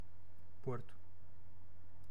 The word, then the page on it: porto: /ˈpoɾ.tu/; /ˈpɔɾ.tu/